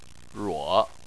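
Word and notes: ruo: tone 3

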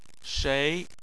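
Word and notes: shei: tone 2

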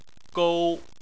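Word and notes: gou1: tone 1

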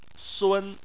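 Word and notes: sun: tone 1

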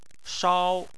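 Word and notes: shao: tone 1

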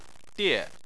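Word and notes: die: tone 4

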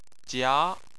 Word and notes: jia2: chinese

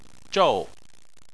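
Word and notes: zhou: tone 4

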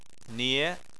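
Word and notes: nie: tone 2

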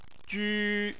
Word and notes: ju1: chinese

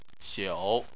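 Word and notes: xiu: tone 3

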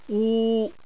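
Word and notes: wu: tone 1

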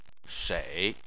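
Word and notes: shei: tone 3

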